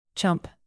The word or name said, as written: chump